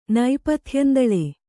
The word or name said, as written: ನೈಪಥ್ಯಂದಳೆ